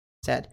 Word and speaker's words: said: Said. A gente tem algo muito específico, muito importante, que é a pronúncia. Não é, é say, presente, e o passado e particípio não é said, é said. Said.